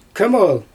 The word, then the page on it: kImol: Xyzyl